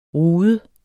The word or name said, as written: rude